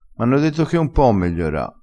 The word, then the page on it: megliora: dialettale